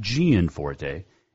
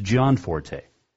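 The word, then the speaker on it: Gianforte: Yes, John Forte. According to his Facebook, that is how you pronounce it. It is not Gian Forte. It is John Forte.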